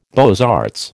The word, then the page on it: beaux arts: American English